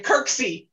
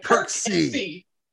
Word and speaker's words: Kirksey: You're fine. Kirksey. Like Kirksey.